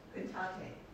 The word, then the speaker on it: Cantate: Today is Cantata Sunday. I think that's how you say it. How do we say it? – Cantate. – That's right. Thank you very much. Cantate is Latin for sing.